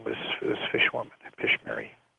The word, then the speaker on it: Pishmeri: Pishmeri. It's P-I-S-H-M-E-R-I, and that's pigeon, New Guinea pigeon.